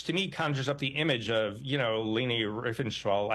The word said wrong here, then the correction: Leni Riefenstahl: I'm mispronouncing her name. The Leni Riefenstahl. – German propagandist, yeah. You know, yeah, thank you.